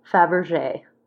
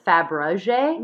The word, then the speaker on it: Fabergé: Carl Fabergé. I always pronounce it Fabergé. – Me too. – But that's actually wrong. It's Fabergé.